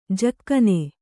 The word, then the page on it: ಝಗನೆ: kannada